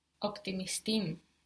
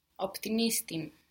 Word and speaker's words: optimistim: No, you are putting the stress on the end. Optimistim, is this correct? But in normal speech, you're going to be putting it actually just before that im. – Optimistim.